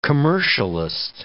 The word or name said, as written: commercialist